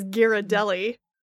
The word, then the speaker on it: Ghirardelli: Okay, Princess Canada, why don't you tell us how it's supposed to be pronounced? – I'm going to take that moniker and own it. The decree from Princess Canada is Ghirardelli.